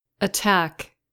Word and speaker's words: attack: Can you hear the difference between the two letters A of the last word? Let's listen again. – Attack. – The first syllable made the quick schwa sound, a.